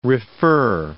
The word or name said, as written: refer